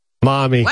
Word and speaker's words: Mami: This year, one of the opening acts was a rapper named Face Tat Mammy. – Mommy. – What? – Mommy, but it's spelled M-A-M-I. Mammy.